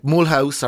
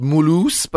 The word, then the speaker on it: Mulhouse: And from there, you go on trips like Mulhouse, or it's pronounced Mulhouse, by the way. I was pulled up on that recently.